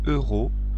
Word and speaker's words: euros: Par exemple, vous voulez un billet aller-retour Paris-Lyon, vous payez 35 euros au lieu de 70 euros. Attention, il faut bien prononcer euros. Euros. 70 euros. 35 euros. C'est une carte très intéressante.